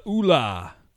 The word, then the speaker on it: Oola: O-O-L-A. I think it's probably pronounced Oola. – I would imagine.